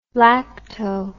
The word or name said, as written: lacto